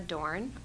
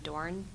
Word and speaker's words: Dorn: Rebecca Dorn, R-E-B-E-C-C-A, last name Dorn, D-O-R-N.